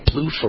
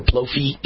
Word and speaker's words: Plouffe: I think I'm pronouncing that right. Plouffe or Plouffe? Plouffe? I think it's Plouffe.